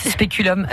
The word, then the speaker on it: Speculum: je le prononce bien. Mon latin est un peu vieillissant. – Hein, bah, on ne prononce pas en latin. vous Dites Speculum.